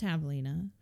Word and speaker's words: javelina: And if you are wondering what a javelina is, and it looks like javelina to the untrained eye. – Starts with a J. – It's pronounced javelina.